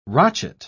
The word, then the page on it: rochet: British English